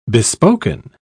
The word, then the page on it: bespoken: British English